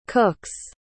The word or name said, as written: cook's